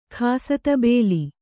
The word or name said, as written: ಖಾಸಾತಬೇಲಿ